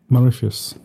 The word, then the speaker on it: Mauritius: Mauritius. – Mauritius. That sounds like a Roman emperor, you know? – I am Mauritius. – Mauritius Maximus. – Mauritius Maximus Decimus Meridius. – Oh, yeah. He said Meridius Mauritius.